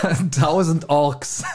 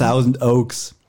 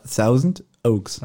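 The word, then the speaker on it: Thousand Oaks: 1000 Orks.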